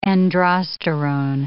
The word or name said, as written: androsterone